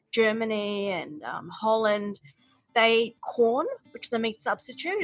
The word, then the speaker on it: Quorn: Brooke went on to explain that in some parts of Europe, they eat a lot of corn. That's spelled Q-U-O-R-N.